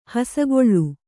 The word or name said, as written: ಹಸೆಗಲ್ಲು